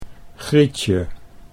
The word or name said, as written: Grietje